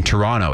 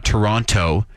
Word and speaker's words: Toronto: all of their production on the air pronounced the second T in Toronto. It's not Toronto. It's Toronto. And it's like, well, then why the hell is the second T there? You know,